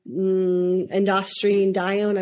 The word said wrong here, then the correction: androstenedione: I don't think I said that right. B, melatonin. C, aldosterone. D, cortisol. E, angiotensin II. Androstenedione. Yep. There you go. – Androstenedione, yeah, which is the answer here.